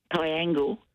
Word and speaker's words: Piangil: Is it Piangil? How do you pronounce that? P-I-A-G-I-L? – Piangil. – Piangil, thank you.